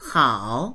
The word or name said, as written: hao3